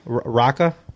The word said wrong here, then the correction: Rooker: Rooker. I can't say that wrong.